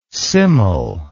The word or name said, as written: simul